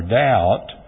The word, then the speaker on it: doubt: The English word for doubt is from the Latin dubitare. Have you ever wondered why doubt is spelled with a D, but you don't a B, D-O-U-B-T, but you don't pronounce the B?